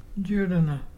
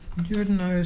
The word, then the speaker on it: Juruna: The word today is Judana. Judana is pelican, and to spell it is J-U-R-U-N-A.